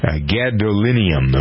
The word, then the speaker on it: gadolinium: A lot of talk this week about gadolinium. Am I pronouncing that right? – Good job.